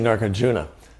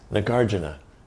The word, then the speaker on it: Nagarjuna: I say Nagarjuna. Another common pronunciation is Nagarjuna, kind of flows, you know.